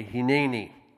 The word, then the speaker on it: hineni: Everybody say hineni. Say it like you're a good Hebrew.